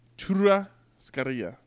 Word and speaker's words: churrascaria: I believe t- to truly pronounce it would be churrascaria.